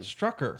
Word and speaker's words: Strucker: I said Stoker. Stalker. – Stoker. – What is it, then? – Strucker. – Strucker. Strucker. – No, it's not Strucker.